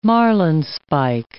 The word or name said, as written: marlinspike